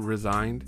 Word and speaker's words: re-signed: which is why I believe he decided to resign? Because I don't want it to. I hate how that almost sounds like the word resigned instead of, if you don't emphasize the word re-signed. Sorry, I'm weird. Re-signed with the Broncos for another, I believe he's on a one-year deal.